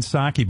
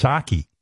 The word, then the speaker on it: Psaki: It's Jen Psaki, but there's silent P up front. It's a weird name. It's like if I spell my name Howard P S T E R N, but I was Howard Stern, but it's Psaki, Jen Psaki.